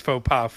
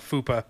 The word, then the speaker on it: faux pas: I mean, again, before we started, I talked about the boss who mispronounced faux pas fupa.